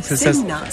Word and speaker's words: Seina: Alors ici, cet espace gastronomique, Sena, Seina. – Ça s'écrit un peu comme la Seine, mais... – Voilà, avec un A à la fin, Seina, on prononce le I aussi.